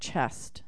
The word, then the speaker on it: chesed: I guess it's pronounced chest, C-H-E-S-E-D, which is another word for divine relief.